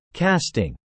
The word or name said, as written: casting